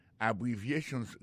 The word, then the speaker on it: abbreviations: En parlant de accent, accent tonique qui vient pour nous prononcer mot yo, n'a pas un mot abbreviations nan qui signifie abréviation. Abbreviations, accent tonique là, tombé sous troisième syllabe là.